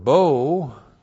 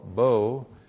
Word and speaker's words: bo: And then the verb bo, be long o aleph, bo.